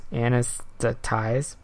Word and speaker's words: anesthetize: It's like anesthesia, but instead of the shiza part, it's ties. Anesthetize.